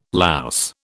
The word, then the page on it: louse: British English